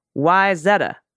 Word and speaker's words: Wayzata: And Wyzata. Wyzetta.